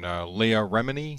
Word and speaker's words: Leah Remini: And Leah Remini, is that how you say her name? – Yes.